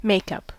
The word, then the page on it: makeup: American English